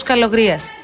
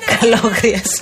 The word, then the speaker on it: Καλογρία: Γιώργο Καλογρία. Mm. Σωστά το είπα. – Ναι, ναι. – Καλογρία είναι.